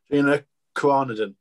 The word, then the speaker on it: Gina Carano: Is it Gina Carano? – Gina Carnadon. – There we go. – Gina Carano, the Mandalorian.